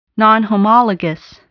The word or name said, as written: nonhomologous